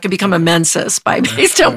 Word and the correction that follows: Mensa: what Mensis, is it Mensis? – Mensa. – Mensa. Yeah. I knew I wasn't saying that right.